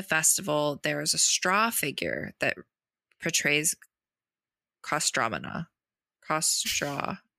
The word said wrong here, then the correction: Kostroma: Why did I forget how to say this? Kostroma. There we go.